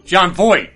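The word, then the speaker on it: Jon Voight: Am I saying his name right? – I don't know. I don't know who you're talking about. – John Voight. – Oh, John Voight.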